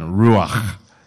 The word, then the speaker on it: ruach: It's called ruach. Okay, you got to like clear your throat at the end. Ruach. It's spelled R-U-A-K-H.